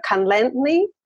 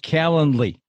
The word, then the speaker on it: Calendly: You said, do you have something like a Calendly? I don't know how you pronounce it, but Calendly, the calendar? – Calendly. – Calendly. – Yeah.